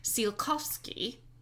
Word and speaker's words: Tsiolkovsky: Although not involved directly in the creation of the space program, the spiritual grandfather was early rocket scientist Konstantin Tsiolkovsky.